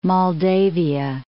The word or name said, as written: Moldavia